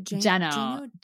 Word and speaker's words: Geno: Well, because it's spelled like Americans would read it Gino, but technically it's Geno. We understand that we're pronouncing it wrong and we'll try to remember to pronounce it right.